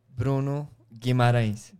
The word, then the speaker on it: Guimarães: Wait, how do you pronounce his name? Can you say it? – Bruno Guimarães.